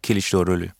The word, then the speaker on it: Kılıçdaroğlu: Kılıçdaroğlu.